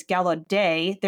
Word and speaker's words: Gallaudet: Justin rang in and said, "What is Gallaudet? There is a T at the end." So he guessed that it was a French pronunciation with a silent T.